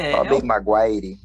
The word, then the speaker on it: Tobey Maguire: Cara, esse nome é muito ruim de falar, né? Tobey Maguire, eu acho que eu sempre tô falando errado. Não sei vocês. – Maguire. Tobey Maguire.